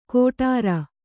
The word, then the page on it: ಗೊಟರ್: goṭar